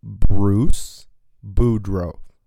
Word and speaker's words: Bruce Boudreau: Bruce Boudreau.